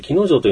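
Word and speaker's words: 鬼ノ城: その海のところに切り立った崖があって、その上に城があるということで、で、木の城というのは漢字で書くと鬼の城と書いて、木の城なんですけども、こちらはですね、えー、まあ、昔から伝わっている言葉で言うと、木の城と呼ばれていたようです。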